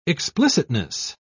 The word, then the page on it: explicitness: British English